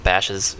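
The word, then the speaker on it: Bashas': Bosch's. It's owned by them. – Bash's? – It's owned by them, it's but Bash's. It's not them. Oh, Bash's. It's spelled like Bauhaus, but with like a missing U.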